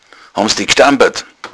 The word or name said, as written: stampern